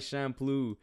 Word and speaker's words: Champloo: Moving on to Samurai Champloo. Did I say that right? I don't know how the fuck to say this. – Shampoo. Yeah, just think of shampoo and then add an L.